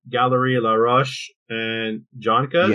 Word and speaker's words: Galerie Laroche and Joncas: Represented by Galerie Laroche and Joncas.